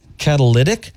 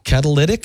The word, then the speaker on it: catalytic: How do you say it? – Catalytic? – Yeah. – What did I say? – Catalytic. – Like athletic. – Catalytic? – Mm-hmm.